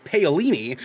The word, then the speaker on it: Paolini: The series would be based on the Christopher Paolini, I think is how you pronounce Paolini.